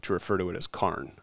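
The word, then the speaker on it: Cairjn: So I was actually talking to somebody from Australia, and so the word Karn is Gaelic in nature, and so when she pronounced it Karn. And so that kind of stuck as like the pronunciation of the word.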